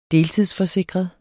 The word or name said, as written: deltidsforsikret